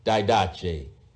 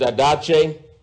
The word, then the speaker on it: didache: Didache. D-I-D-A-C-H-E. Or you might look at it as didache, but it is pronounced didache, and it is translated doctrine in the King James Version.